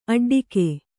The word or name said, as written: ಅಡ್ಡಿಗೆ